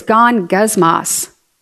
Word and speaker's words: gongusmos: The word is gongusmos, G-O-N-G-U-S-M-O-S.